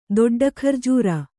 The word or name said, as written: ದೊಡ್ಡಖರ್ಜೂರ